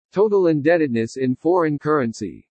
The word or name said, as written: TOTAL INDEBTEDNESS IN FOREIGN CURRENCY